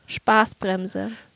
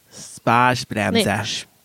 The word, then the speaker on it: Spaßbremse: Spaßbremse. – Spaßbremse.